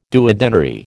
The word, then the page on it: duodenary: American English